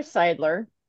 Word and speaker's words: Seidler: We're pleased to welcome today to the table Taylor Seidler.